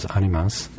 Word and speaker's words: Animas: And Animus is probably better pronounced. The Spanish pronunciation is Animas. – Mm-hmm. – And the spelling is A-N-I-M-A-S.